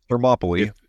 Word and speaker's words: Thermopylae: Thermopylae. That's how they say it, yeah. I think it's supposed to be Thermopylae.